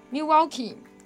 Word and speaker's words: Milwaukee: Milwaukee.